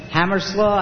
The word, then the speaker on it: Hammerslough: My name is Phil, that's P-H-I-L. Hammerslaw, I better spell that for you. It's H-A-M-M-M-E-R-S-L-O-U-G-H. – H-A-M-M-E-R-S-L-O-U-G-H.